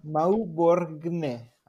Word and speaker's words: Mauborgne: Y el número dos es una recomendación de un libro que se llama La estrategia del océano azul de René Maupurga. No, no, no, Okay. – no sé cómo se escribe, pero es Mauborgne, así se escribe.